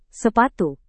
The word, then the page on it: sepatu: Indonesian